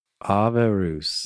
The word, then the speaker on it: avarus: Okay, so you got to say it like a Latin person. Say it like a Latin person. – Avarus. – There you go.